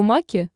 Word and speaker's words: маки: Экскурсовод назвал его маки с ударением на последнем слоге, а потому французские партизаны, которые там прятались, так и назывались – маки или макизары.